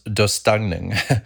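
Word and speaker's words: döstädning: So Swedish death cleaning, I believe it's pronounced dostagning, is basically not about just clearing out closets.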